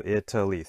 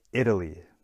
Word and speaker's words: Italy: So Italy, three syllables in the country. One more time. Italy. Okay. So if you said the first syllable, it, see that it gets the stress.